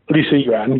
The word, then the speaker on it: Li Xuan: He was born, am I pronouncing this correctly, Li Xuan? – Li Xuan, yes.